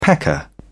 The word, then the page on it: pecker: British English